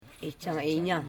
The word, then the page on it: ichainya: i.cha.i.nya